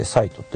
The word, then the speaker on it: 西都: 今日の最高気温は宮崎県の西都、西の都と書いて西都と読むみたいですけどね、 30.3 度が一番どうも暑かったみたいですね。